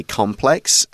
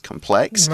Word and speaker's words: complex: Um, by the way, you will notice that I will usually say complex, and Leah says complex. – Right. Absolutely fine. It's just a difference between uh, British and American pronunciation.